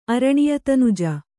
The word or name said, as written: ಅರಣಿಯತನುಜ